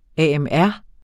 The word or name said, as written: AMR